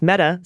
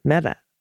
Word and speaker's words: Mehta: Amit Mehta is pronounced Amit. Equal emphasis on both syllables. Mehta, slight emphasis on the meh. And the ta is pronounced like the Spanish, with the tongue at the tip of the teeth.